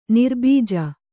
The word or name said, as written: ನಿರ್ಬೀಜ